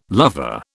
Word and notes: lover: British English

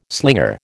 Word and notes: slinger: American English

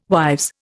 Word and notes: wives: Indian

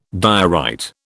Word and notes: diorite: British English

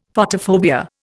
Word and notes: Photophobia: Indian

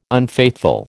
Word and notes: unfaithful: American English

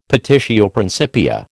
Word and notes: petitio principia: American English

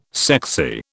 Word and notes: sexy: British English

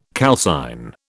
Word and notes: calcine: British English

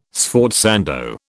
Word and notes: sforzando: British English

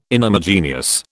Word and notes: inhomogeneous: British English

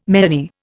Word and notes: meany: Indian

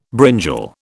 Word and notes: brinjal: British English

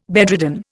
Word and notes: bedridden: Indian

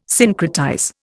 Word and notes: syncretize: Indian

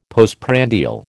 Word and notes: postprandial: American English

